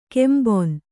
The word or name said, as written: ಕೆಂಬೊನ್